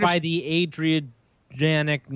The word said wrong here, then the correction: Adirondack: We got more interaction about screwing up Adirondack.